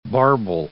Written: barbel

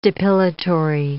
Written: depilatory

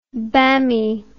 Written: bammy

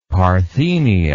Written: parthenia

